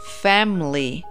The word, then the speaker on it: family: En realidad, familia con la I latina que tiene ahí en esa palabra, no vamos a pronunciar la I latina, vamos a decir family, family.